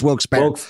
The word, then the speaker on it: Wilkes-Barre: It's Wilkes-Barre. – Wilkes-Barre. – I'm sorry, it's Wilkes-Barre. I went to school there. – Oh, you should know. – Yeah. I should know. It's Wilkes-Barre.